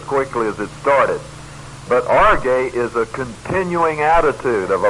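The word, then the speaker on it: orge (ὀργή): O-R-G, long E, orge.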